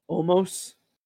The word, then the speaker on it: Omos: Omos. – Did I say it right? Yeah, Omos. Yes. This was Omos. So it's like Almost. Almost.